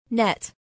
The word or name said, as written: net